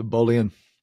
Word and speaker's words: Bolian: It's like Kardashian. So Bolian, perfect.